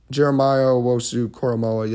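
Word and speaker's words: Jeremiah Owusu-Koramoah: The one thing I will say, though, is again with Jeremiah Owosu Koromora, I got to pronounce that better. That was really bad. Jeremiah Owosu Koromoa, yeah, there we go.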